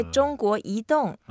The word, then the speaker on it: 中国移动: So, next time when you have a meeting with the CEO, you say 中国移动.